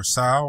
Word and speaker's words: SOW: So, well, you know, it's spelled sow like the pig, you know, mm-hmm. So that's kind of the joke. But, you know, I kind of made it seem like it's pronounced sow, like you're sowing the seeds of success, right?